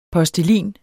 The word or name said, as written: POSTELIN